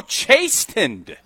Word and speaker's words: chastened: C-H-A-S-T-E-N-E-D. – Oh, chastened. – I thought it was... – You said chastened. – No, the T is silent.